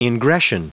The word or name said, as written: ingression